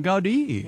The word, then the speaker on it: Gaudí: But Gaudí is really, he had quite the vision for that. – Oh, Gaudí. – Yeah, that's how they pronounce it.